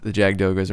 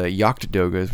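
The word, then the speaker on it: Jagd Doga: To answer your question, the Jagdogas or the Yachtdogas, which I believe is the correct pronunciation.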